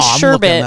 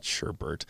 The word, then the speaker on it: sherbet: And sherbet. That's how it's pronounced. Look it up. – Sherbert. – Instead of sherbert.